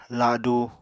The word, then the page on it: ladu: Apatani